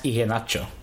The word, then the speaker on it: Iheanacho: Iko Nacho or something? Is that his name? – It'd be difficult to Kelechi Iheanacho. I think it's pronounced Iheanacho.